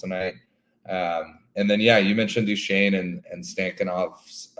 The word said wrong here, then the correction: Stankoven: We got to get you to pronounce that properly. He's on your team, for crying out loud. Stankoven. There you go. – Stankoven.